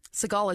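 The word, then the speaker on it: Segel: And I loved, like, the new movie that came out in 2011, The Muppets, with Adam Segal and wait, did I say that right? – No. – And Amy Adams. Who's the main guy? – Seagal is right.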